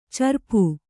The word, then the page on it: ಚರಪು: kannada